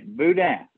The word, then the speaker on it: boudin: Am I pronouncing all of this right? Welcome back. How are you? – I'm doing good. And yes, you are pronouncing it right. Boudin.